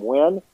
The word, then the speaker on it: Wynn: You can search it either by the title, We the People, and my last name, Wynn, or you can search by my name, H. Edward Wynn, and it's spelled W-Y-N-N.